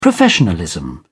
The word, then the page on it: professionalism: British English